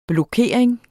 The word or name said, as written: blokering